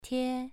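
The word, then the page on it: tie: tone 2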